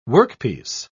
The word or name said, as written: workpiece